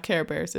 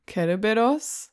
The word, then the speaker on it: Cerberus: No, it's not Care Bears. It's Kereberos. – Yeah. – So they pronounce it with a very hard K.